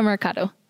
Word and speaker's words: Mercado: Angelica Mercado.